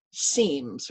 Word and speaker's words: Siems: One of my favorites, and it's totally appropriate for this season, is a humble home economist from Indiana named Ruth Seams. Her name is spelled S-I-E-M-S.